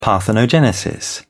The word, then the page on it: parthenogenesis: British English